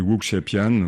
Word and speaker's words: wuxia pian: Oui, Wuxia Pian, oui, donc ça veut dire film de sabre, mais ça participe du kung-fu.